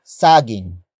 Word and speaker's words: saging: And that is saging. Saging. Saging. Saging is spelled as S-A-G-I-N-G.